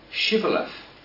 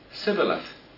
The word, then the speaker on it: Shibboleth: To prove that they were Israelites, they were asked to say, Shibboleth. Those who could not form the lips to pronounce the word correctly said, Sibboleth.